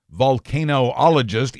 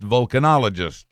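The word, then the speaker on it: vulcanologist: Volcanologist is clumsy to say, so it's pronounced volcanologist, as in Mr. Spock.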